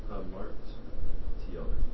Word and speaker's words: Hamartiology: Harmatiology? – There you go. – Harmatiology? – Yeah. – Can I have you spell that? – Or Hamartiology. It's Hamart. Hamartiology.